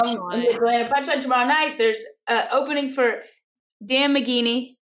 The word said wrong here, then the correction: Mangini: Mangini. – I said it right. – No, you didn't. You forgot the N in there. Mangini.